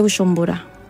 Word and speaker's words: Bujumbura: La capital es Bujumbura. – Ah, bueno, encontré mal. – J de ustedes para mí es yo. Es como Shu.